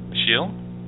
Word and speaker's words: Ashil: No, I was just going to say my name is Ashil. A-S-H-I-L. – Ashil? – Ashil. A-S-H-I-L. – Okay. – A-S-H-I-L.